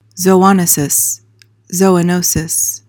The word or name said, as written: zoonosis